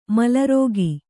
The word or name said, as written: ಮೇಲಾರೈಕೆ